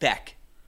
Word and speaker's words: Baik: It's more like Beck, and I was pronouncing it in the Americanized way.